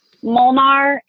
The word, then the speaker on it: Molnar: My Instagram name is Megan Molnar. Megan is spelled a little funky. It's M-A-E-G-A-N Molnar, M-O-L-N-A-R.